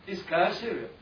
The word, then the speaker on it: Caesarea: When Jesus came into the coast of Caesarea, Philippi, you know this Caesarea, that's the way I think the American pronounce it. – Caesarea. – But in the Greek, it's Caesarea.